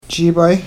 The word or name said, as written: jiibay